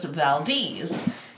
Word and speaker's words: Valdez: And as I discovered, it apparently is Valdez. Because we were talking about that over whether it was Valdez or Valdez. It's spelled Valdez. And it goes against every ounce of my South Florida born and raised instinct to say Valdez. However, it does appear that is how it is pronounced in this case.